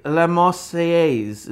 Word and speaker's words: La Marseillaise: So he would play La Marseillaise.